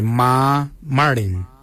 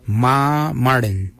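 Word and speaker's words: marrdiny: Ma Kuju is spelled M-A-R-R, then it's G-O-O-D-J-A-L. For 8 we say Ma Marin. Ma Marin is number 8. Ma is spelled M-A-R-R, then it's M-A-R-R-D-I-N-Y. Ma Marin for number 8.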